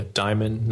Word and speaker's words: Dimon: So my name is Garrett Dimon. And yeah, Dimon, not Dimon or Demon or Dimon.